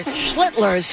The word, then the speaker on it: Schlittler: This is Cam Schlittner's... Schlittler's, excuse me.